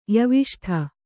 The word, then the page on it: ಯವಿಷ್ಠ: Kannada